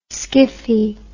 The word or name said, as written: skiffy